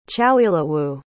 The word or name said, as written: chowilawu